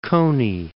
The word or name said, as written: Coney Island